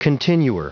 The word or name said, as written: continuer